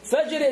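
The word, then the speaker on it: فجر: Like Fajr. Fajr is F-A-J-R. If you write it F-A-J-A-R, it's called Fajr. Which is not the Arabic word, not Fajr.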